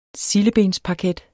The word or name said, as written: sildebensparket